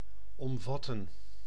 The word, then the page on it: compose: English